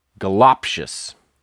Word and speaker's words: Goloptious: And uh, goloptious? It's the lop, the second syllable.